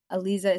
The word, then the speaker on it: Aleeza: Aliza is spelled A-L-E-E-Z-A, two E's.